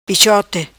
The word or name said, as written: Pichote